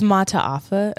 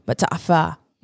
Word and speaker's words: Mata'afa: There's like a little apostrophe in the middle because there's two A's. But yeah, that's how you're supposed to pronounce it, but even I butcher it, so I just say Mata'afa.